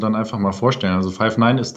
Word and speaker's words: Five9: Ja, ist richtig. Five9 wird es ausgesprochen.